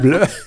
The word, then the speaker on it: Bleu: Das wird genauso ausgesprochen. – Ja, Bleu.